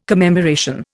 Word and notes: commemoration: Indian

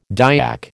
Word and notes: Dyak: American English

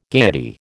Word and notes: gayety: American English

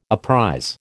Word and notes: apprize: American English

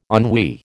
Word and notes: ennui: American English

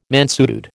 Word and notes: mansuetude: American English

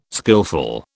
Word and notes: skilful: British English

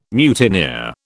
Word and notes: mutineer: British English

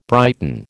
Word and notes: brighten: American English

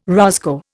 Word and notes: roscoe: Indian

